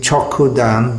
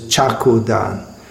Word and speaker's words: Chokudan: So we sing Chokudan, but it's somehow or other many devotees pronounce this as Chakudan. So in Chaku in Bengali means a knife.